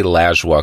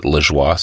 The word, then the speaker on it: Lajoie: My father, Paul-Émile Lajoie, or I say Lajoie because that's how we pronounce it in Quebec, but everyone else pronounces it Lajoie, so I've shifted the pronunciation, funny enough.